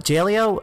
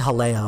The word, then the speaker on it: Jaleo: So and it's kind of similar to uh, uh, Jaleo. Uh, actually, it's probably Haleo. I think that's how it's actually pronounced.